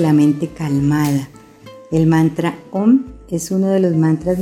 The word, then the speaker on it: Om: Se pronuncia Om, pero en realidad se escribe Aum, A-U-M, y esto es debido a que el análisis de la letra O en sánscrito representa la conjunción de las letras A y U.